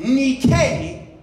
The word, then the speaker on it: Nike: The word Nike, pronounced in Greek, Nike, is Greek for the word victory.